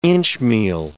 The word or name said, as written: inchmeal